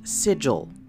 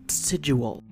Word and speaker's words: sigil: And before I step down from my soapbox, it's pronounced sigil, not sigil. It rhymes with vigil, which also isn't pronounced vigil.